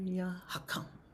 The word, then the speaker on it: Hakam: We're going to say Yanur slightly on the tone, and Hakam is like a little short. It's like the emphasis on the k sound. So, Yanur Ya Hakam.